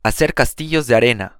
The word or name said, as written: hacer castillos de arena